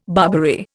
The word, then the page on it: Barbary: Indian